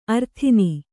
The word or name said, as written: ಅರತ್ನಿ